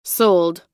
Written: soled

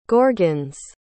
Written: gorgons

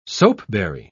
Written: soapberry

